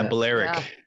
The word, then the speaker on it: Balearic: but Balearic music, which basically means music played on Ibiza. – Yeah, Balearic.